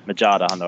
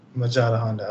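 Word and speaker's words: Majadahonda: The game that they played last, it was against Maja Honda. I think that's how you pronounce their name. – Yeah, Majada Honda. I think they got the extra D in there. – Oh, there's a D in there? – Yeah.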